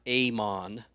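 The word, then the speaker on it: amon (אמן): It's the word iman. It's pronounced amon.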